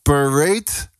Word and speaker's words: Parade: Parade. Nu spreek je het laatste deel uit alsof je het over een kont hebt.